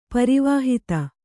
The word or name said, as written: ಪರಿವಾಹಿತ